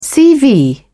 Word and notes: cv: British English

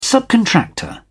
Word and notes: subcontractor: British English

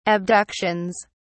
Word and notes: abductions: British English

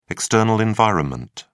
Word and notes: external environment: British English

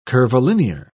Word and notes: curvilinear: British English

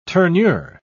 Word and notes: tournure: British English

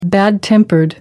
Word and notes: bad-tempered: American English

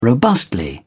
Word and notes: robustly: British English

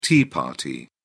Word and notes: tea party: British English

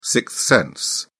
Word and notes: sixth sense: British English

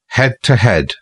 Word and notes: head-to-head: British English

